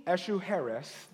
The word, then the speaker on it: Ahasuerus: And King Ahasuerus, that's how you pronounce it.